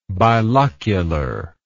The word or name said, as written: bilocular